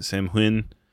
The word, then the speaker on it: Samhain: that they say Samhain. I know I pronounced it correctly in the first portion, but I can't remember. Samhain is how you correctly pronounce it.